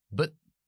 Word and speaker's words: but: No decimos but, decimos but, but, y muchas veces ni pronunciamos la T.